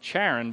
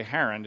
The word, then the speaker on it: Haran: And he came out of the land of the Chaldeans and dwelt in Charan, which is actually Haran, it's just a pronunciation.